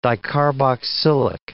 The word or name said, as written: dicarboxylic